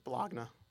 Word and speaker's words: Bologna: It's Bologna. – It's how you'd pronounce it, Bologna, but it's B-O-L-O-G-N-A. – Thank you.